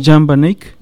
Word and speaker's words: Jan van Eyck: Jan van Eich, no sé cómo se ah, eh, pronuncia. Jan Van Eyck.